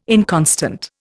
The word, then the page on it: inconstant: Indian